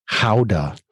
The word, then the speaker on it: Gouda: Gouda. The G's in all Dutch are kind of silent or glottal? – They're kind of glottal unless okay.